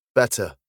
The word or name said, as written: better